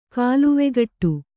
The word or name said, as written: ಕಾಲುವೆಗಟ್ಟು